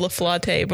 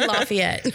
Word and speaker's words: Lafayette: I've heard it called something, but I can't remember what it is right now. – Yeah, I've definitely heard it called La Flotte before. – Spell it. Oh, you mean Lafayette.